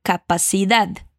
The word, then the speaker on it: capacidad: Outra vez, e cuidado com a pronúncia desse dezinho no final da palavra capacidad. Vai lá. ¿Hay alguna habitación con capacidad para todos?